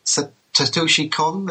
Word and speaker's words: Satoshi Kon: I mean, since we were away, Satoshi Kon, the director of...